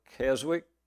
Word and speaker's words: Keswick: This started in a town called Keswick, spelled Keswick, pronounced Keswick, okay?